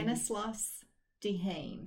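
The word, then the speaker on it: Stanislas Dehaene: Stanislaus Dehaene. – Dehaene.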